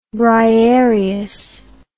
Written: Briareus

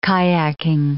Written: kayaking

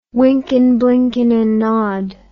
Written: wynken blynken and nod

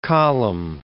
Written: Colum

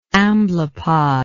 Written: amblypod

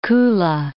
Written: Kula Gulf

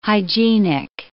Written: hygienic

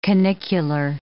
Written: canicular

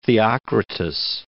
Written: Theocritus

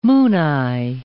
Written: mooneye

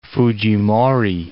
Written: Fujimori

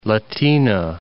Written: Latina

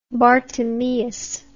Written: Bartimaeus

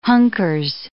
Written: hunkers

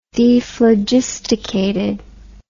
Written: dephlogisticated

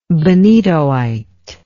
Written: benitoite

